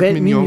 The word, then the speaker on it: Mignon: Oder spricht man es französisch Mignon, aus? ich glaube, man sagt Mignon.